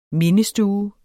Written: mindestue

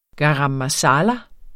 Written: garam masala

